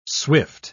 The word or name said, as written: swift